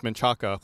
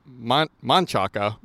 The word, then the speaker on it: Manchaca: It's off Menchaca, which used to be Manchaca, but it's pronounced Manchac.